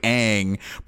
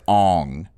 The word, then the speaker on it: Aang: It's actually Ang, but in the movie they pronounce it as Ong.